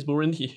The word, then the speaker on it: Burundi: You're not butchering it, right? Like, that's the real name, right? Like, yeah, it's Burundi.